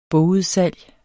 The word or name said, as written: bogudsalg